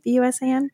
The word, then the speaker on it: Busan: Am I saying that right? BUSAN, Busan, I think.